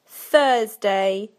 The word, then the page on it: Thursday: British English